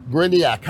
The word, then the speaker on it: Gryniak: Gryniak, how are you doing? – Yes, good.